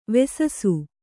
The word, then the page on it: ವೆಸಸು: Kannada